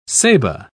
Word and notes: ceiba: British English

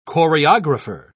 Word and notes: choreographer: British English

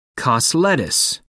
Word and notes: cos lettuce: American English

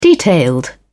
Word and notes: detailed: British English

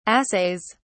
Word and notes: assays: British English

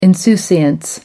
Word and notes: insouciance: American English

